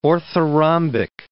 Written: orthorhombic

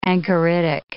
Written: anchoritic

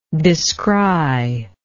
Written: descrier

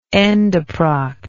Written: endoproct

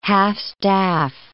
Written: half-staff

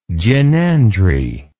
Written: gynandry

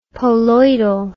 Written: poloidal

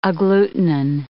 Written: agglutinin

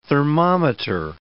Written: thermometer

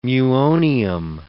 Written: muonium

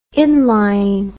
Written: inlying

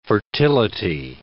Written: fertility

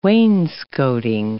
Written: wainscoting

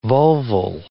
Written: vulval

